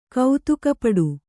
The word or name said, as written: ಕೌತುಕಪಡು